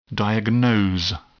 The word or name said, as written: diagnose